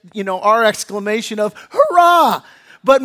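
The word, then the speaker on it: hurrah (χαρά): But in the Greek, it's pronounced hurrah. Hurrah, right?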